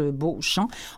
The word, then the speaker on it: Beauchamp: de Pierrette Beauchamp, mais comme ça se prononce, Beauchamp, en un seul mot.